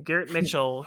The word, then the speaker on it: Garrett Mitchell: Garrett Mitchell, who we drafted 2 years ago.